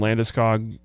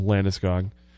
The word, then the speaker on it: Landeskog: You know, there have been rumors about him and Landeskog being on the move. Did I pronounce his name okay? – You did. You nailed that one. – Thanks. Gabriel Landeskog.